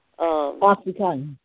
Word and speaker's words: OxyContin: How are you going to get the Oxycontin, if I'm pronouncing it right? – Oxycontin.